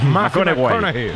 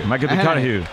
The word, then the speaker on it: McConaughey: Mateo McConaughey. – Sí, sí, sí, sí, sí. – Hay que ir corrigiendo la pronunciación. – McConaughey. McConaughey.